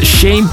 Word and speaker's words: Shane: Dan het Shane Paul. En het is niet dat we verkeerd uitspreken dat de Sean is.